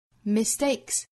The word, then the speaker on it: mistake: 好，下面我们来用非常慢的速度用英音读一下这句话 Mistakes are what makes us human, but forgiveness, that gives us humanity. 好，下面我们再看美音的发音。 Mistakes are what makes us human, but forgiveness, that gives us humanity. 首先第一句话里 Mistakes are what makes us human. 同样我们注意的呢就是 mistake 这个词重读在第二个音节上。